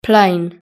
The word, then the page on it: plane: British English